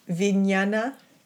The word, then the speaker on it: Vijnana: Vijnana. – Mm-hmm. – Vijnana Maya Kosha. And Vijnana Maya Kosha. – Yeah, Vijnana. – Yeah, because often Vijnana. The J N is nya.